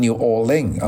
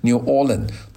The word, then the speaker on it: New Orleans: 今天呢就和大家讲一讲这个事情呢，就是在路易斯安那州啊，有个最大的城市叫 New Orleans 啊，纽奥尔良市，有些人把它读成 New Orleans 啊，有些人读成 New Orleans，都有这两个读法。